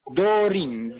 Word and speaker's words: Dorin: Dorin, D, O, R, I, N.